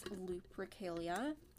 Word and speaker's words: Lupercalia: I think it's Lupercalia. I don't know why I pronounce it loop. Like, I don't know why I pronounce it with a B. Like, that it sounds like there's a B in it when it comes out of my mouth. But it's Lupercalia.